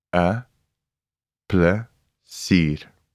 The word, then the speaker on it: 'n plesier: Then we have the article a, which is spelled apostrophe n, and plesier, meaning pleasure. A and plesier. A plesier.